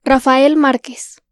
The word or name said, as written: Rafael Márquez